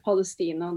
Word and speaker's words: Palestina: The first song is called Bandiera Rosa, and the second song is called Free Palestina. Am I saying that right? – Palestina. That's Palestine in the region.